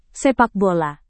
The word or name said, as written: sepak bola